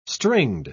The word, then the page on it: stringed: British English